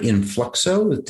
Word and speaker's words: INFLUXO: and now you're the director at INFLUXO.